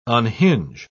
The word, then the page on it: unhinge: British English